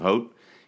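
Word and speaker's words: Hoedt: He's a 23-year-old, and if you couldn't tell by my pronunciation of Hoat, he is a Dutch international.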